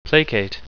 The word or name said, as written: placate